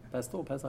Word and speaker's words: pestle: Pestle, how do you pronounce it? It has a silent T or? – Pestle. – Pestle, it's a silent, okay.